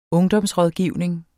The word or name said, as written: ungdomsrådgivning